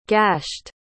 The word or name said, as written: gashed